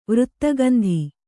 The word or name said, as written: ವೃತ್ತಗಂಧಿ